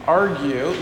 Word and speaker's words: Argue: I'm Deacon Douglas Argue, I can't even pronounce my last name, Argue, like fight, quarrel, argue.